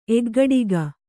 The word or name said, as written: ಎಗ್ಗಡಿಗ